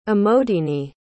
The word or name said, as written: amodini